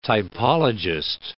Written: typologist

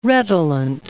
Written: redolent

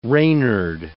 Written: reynard

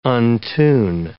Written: untune

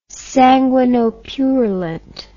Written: sanguinopurulent

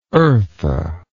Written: eartha

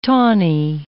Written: tawny

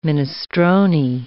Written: minestrone

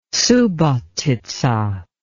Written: Subotica

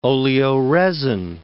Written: oleoresin

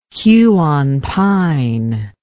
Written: Huon pine

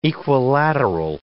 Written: equilateral

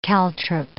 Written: caltrop

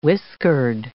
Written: whiskered

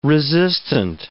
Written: resistant

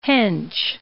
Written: Hench

